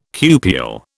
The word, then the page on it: cupule: British English